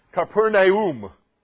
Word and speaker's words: Capernaum: And actually, in the Greek, that is pronounced Capernaum.